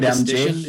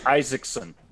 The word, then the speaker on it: Iszczyszyn: You know what it is? It's Isaacson. – Isaacson? – Because I asked Joel about this one before. What's a, it's like, Joel, what is this? He said it's pronounced Isaacson.